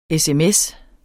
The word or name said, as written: sms